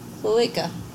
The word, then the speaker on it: cloaca: It's all through one, it's all the cloaca. – Yep. – All in one hole. – That's the correct way to say it. – One hole. – Cloaca.